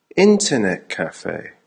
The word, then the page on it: internet cafe: American English